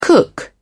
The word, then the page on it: cook: British English